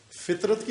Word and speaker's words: فطرت: فطرت کی لفظی ترکیب، یعنی یہی کلمہ فے توئے تے نہیں توئے توئے توئے زوئے توئے رے تے فطرت، لیکن اس تلفظ کے ساتھ فطرت، یعنی فے کے نیچے زیر ہو، فطرت فطرت نہ فطرت، فے کے نیچے زیر ہو، توئے کے اوپر سکون ہو، جزم ہو، اور رے زبر ہو،